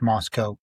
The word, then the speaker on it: Moscow: Doug Wilson is the pastor of a church in Moscow, Idaho, spelled Moscow, but pronounced Moscow, who has formed a denomination, Reformed Calvinist denomination, called the Communion of Reformed Evangelical Churches, or CREC.